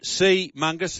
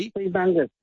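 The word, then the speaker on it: Seamungus: Have you pronounced that again, please? – Sea mungus. That's S-E-A. – Sea mungus. – M-U-N-G-U-S. Sea mungus.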